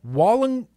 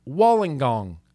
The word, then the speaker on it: Wollongong: Wollongong. Wollongong, Australia. Do you need to say it with an Australian accent? Wollongong. Wollongong. Oh, kinda.